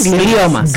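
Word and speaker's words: glioma: ¿Qué son los, cómo se dice, gliomas? – Gliomas.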